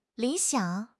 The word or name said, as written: li3 xiang3